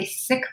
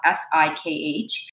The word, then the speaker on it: Sikh: It is spelled S I K H. Many people pronounce it, including my own people, pronounce it as Sikh. That is not the correct pronunciation. That is the colonized pronunciation.